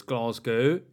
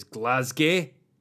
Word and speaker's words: Glasgow: I mean, as we all know, if you know this grand union of ours intimately, as I do, you'll know there are 3 separate pronunciations of Glasgow. There's Glasgow, if you're like me. There's Glasgow, if you sort of think you know Glasgow. And then there's Glasgow, if you're really Glaswegian.